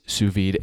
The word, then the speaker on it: sous vide: What is sous vide and am I even pronouncing it correctly? – Sure, you did pronounce it correctly.